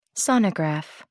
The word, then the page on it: sonograph: American English